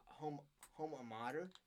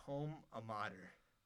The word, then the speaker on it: alma mater: I always say home a mater. – I've never heard someone say home a mater. Like, what? – Alma mater? – Home a mater? Yeah, I don't know where you got that from.